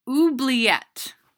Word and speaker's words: oubliette: Oubliette. And I am pronouncing that correctly. – Oubliette. – Oubliette.